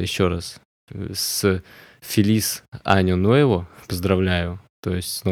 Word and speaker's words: año: Аню в переводе год. А, тут, кстати, главное не произнести или не написать «Ану», иначе вы можете прослыть грубым пошляком.